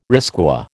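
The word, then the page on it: risqué: American English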